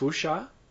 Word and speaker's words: Wuxia: I've always heard it pronounced wuxia. Is wuxia. That not okay. Right? – I'm going to have to go for this one. – I think it's closer to xia. Wuxia.